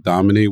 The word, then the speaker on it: Dahomey: Dahomey, which is present day Benin.